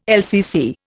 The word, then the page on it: LCC: Indian